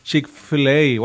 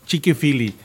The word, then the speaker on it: Chick-fil-A: siempre la pronuncio mal en un restaurante que es Chick-fil-A o algo así, ¿no? Yo le decía Chiqui-fili.